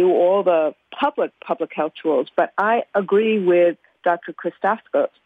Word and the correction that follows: Christakis: if I mispronounced it, that Christakis, thank you,